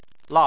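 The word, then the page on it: la: tone 4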